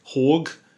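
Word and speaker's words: HÅG: So let me just start by saying that this is a Haag Horg, as it would be pronounced, Capisco Pulse.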